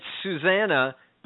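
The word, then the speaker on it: Zsuzsanna: Z-S-U-Z-S. – Right. – So it's Susanna, but the S's are Z-S's instead.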